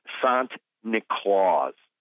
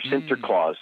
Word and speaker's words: Sinterklaas: Now, the Dutch pronunciation of St. Nicholas is Sant Niklaus, or Sinterklaas, Sant Niklaus.